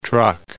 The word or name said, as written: Truk